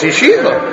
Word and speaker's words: Yeshiva: Because they're thinking of the English word, and there's an I there, not an EE. Maybe. But it's Yeshiva.